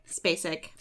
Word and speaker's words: Spacek: Spacek.